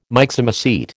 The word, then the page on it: Myxomycete: American English